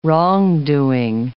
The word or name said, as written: wrongdoing